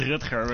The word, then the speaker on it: Rutger: It's Rutger, but it's very, very hard to pronounce, I know.